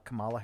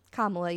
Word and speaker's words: Kamala: President-elect Joe Biden, Vice President-elect Kamala Harris. – Kamala, yeah.